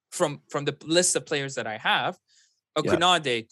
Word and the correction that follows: Koundé: Kunade, sorry. Kunde, or my bad. I don't know how to say his name. It's Julius. That's his first name. – Jules. But Jules Kunde. Kunde. – Yeah, Kunde. Kunde.